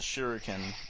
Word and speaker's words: shuriken: And then one of the gang members throws a shuriken and slashes his wrist with it. And I'm very proud of you for pronouncing that properly, by the way.